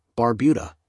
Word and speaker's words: Barbuda: Antigua, pronounced in TGA, and Barbuda, Barbuda, is located in the heart of the Caribbean Sea.